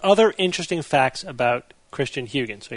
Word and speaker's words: Huygens: It's Christian Huygens, but I actually his name, I listened to the pronunciation of his name online, and if the internet is correct, it's Huygens. Huygens.